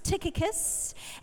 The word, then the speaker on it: Tychicus: Now, I looked this up, and I listened to how to pronounce these names, Tychicus and Onesimus.